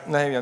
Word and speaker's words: Nehemia: En dat komt uit Nehemia. Uh, zeg ik dat goed? Ja, Nehemia.